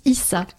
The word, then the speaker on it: Eesah: Bon, ben voilà, si ça vous intéresse, Esa Yasuke, le prénom c'est E-E-S-A-H. – Je crois que ça se prononce Issa, Issa.